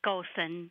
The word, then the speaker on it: Gossen: It's Gossen.